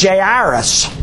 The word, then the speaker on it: Jairus: But he said you pronounce it Jairus.